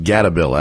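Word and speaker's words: Gatebil: Gate Bill. Kind of. It doesn't seem quite as crazy in terms. It's not. It's more grassroots. Like I believe it's pronounced Gatabill, actually.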